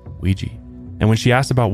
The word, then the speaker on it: Ouija: It's like technically pronounced Ouija. – Ouija? – Yeah, mm-hmm. but over time, we just say Ouija, so.